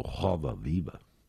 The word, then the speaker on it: Roda Viva: Y ahí estaba acompañado por el MPB4 en uno de sus grandes temas, Roda Viva, que los brasileños pronuncian como Joda Viva.